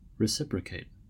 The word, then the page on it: reciprocate: American English